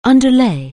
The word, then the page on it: underlay: anglais